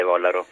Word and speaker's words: Vollaro: Ho sbagliato l'accento? No, l'accento è giusto, sono Michele Vollaro.